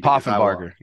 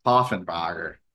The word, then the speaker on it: Poffenbarger: Poffenbarger. – Poffenbarger.